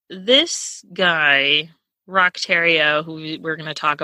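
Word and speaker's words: Roch Thériault: his name is spelled Roche Theriault. That's how I Okay. – would phonetically sound it out. But I listened to I watched an investigative discovery with like video on it, like an hour-long video. And they called him Rock Terrio.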